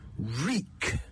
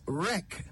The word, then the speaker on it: wreak: The word is reek. W-R-E-A-K. Reek. Many people pronounce it as wreck, but that is an entirely different word with an entirely different meaning.